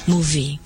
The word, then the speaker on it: movie: movie เนี่ยสะกดด้วยตัว V นะคะเวลาที่เด็กๆออกเสียง movie เด็กๆอย่าลืมเอาฟันบนกับลิ้นปากตั้งเบาๆนะฮะแล้วก็ออกว่า movie